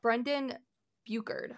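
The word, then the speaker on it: Brendon Burchard: Brendan Buchard, I believe is how it's pronounced. – Yep.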